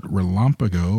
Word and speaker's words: RELAMPAGO: I hope our producers nailed that, but we call it RELAMPAGO, or I hope I'm saying that correctly. But let's start off with sort of a 101 on MCSs and then transition to the broader RELAMPAGO mission. – Yeah, yeah. So, yeah, we'll get to RELAMPAGO in a second. You nailed the pronunciation, which is great.